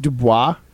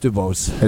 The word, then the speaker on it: Dubose: I mean, the Packers don't like to cut draft picks, but is it pronounced Dubois? – No, it's Dubose.